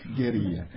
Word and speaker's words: guerrilla: Spanish pronunciation, guerrilla.